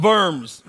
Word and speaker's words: Worms: Worms was a city in Germany, by the way. So if you pronounce it right, it's the Diet of Worms, right?